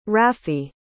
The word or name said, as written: rafee